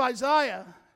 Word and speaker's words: Isaiah: Well, if you understand the book of Isaiah, yes, that's how you say it.